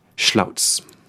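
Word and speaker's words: Slavc: Schlautz. – C'est ça. Mm-hmm. Alors, ça ne s'écrit pas du tout comme ça. – Non, enfin, à moins que vous ne soyez familier avec les langues balkaniques.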